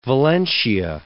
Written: Valentia

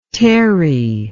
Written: Terri

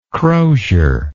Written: crosiered

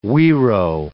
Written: guiro

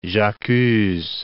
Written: j'accuse

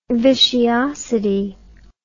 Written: vitiosity

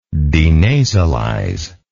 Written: denasalize

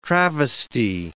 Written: travesty

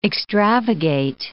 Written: extravagate

